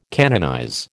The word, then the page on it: canonize: American English